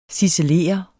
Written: ciselere

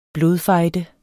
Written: blodfejde

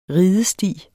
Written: ridesti

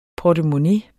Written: portemonnæ

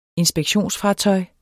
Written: inspektion

